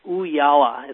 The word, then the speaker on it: Ooyala: So, and we used a service called Ooyala. It's O-O-Y-A-L-A. – Wait, spell that one more time. – It's O-O-Y-A-L-A.